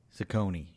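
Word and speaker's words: Cecconi: And the other one I always mispronounce, is it Ciccone? – Ciccone, yeah. – Ciccone, okay.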